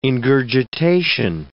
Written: ingurgitation

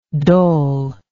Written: dol

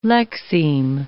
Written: lexeme

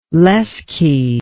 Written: lesche

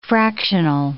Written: fractional

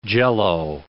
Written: Jell-O

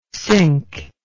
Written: cinque

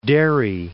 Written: Derry

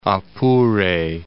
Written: Apure

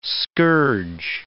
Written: scourge

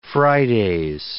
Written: Fridays

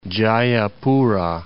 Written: Jayapura

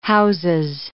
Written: houses

